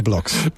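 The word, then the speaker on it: Blockx: In seinem ersten Match, das bald losgeht, da spielt er gegen Alexander Bloks aus Belgien. Ich hoffe, ich habe den richtig ausgesprochen. Unser Reporter für Wimbledon. Hallo erstmal dorthin. – Ja, hallo, ich spreche den genauso aus, Alexander Bloks.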